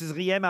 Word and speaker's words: Zriem: Comment je dois prononcer Z-R-I-E-M? – Ah ben, Zrim, tout Zrim. Simplement. Voilà. – Tout simplement, Brigitte Zrim à Auxerre.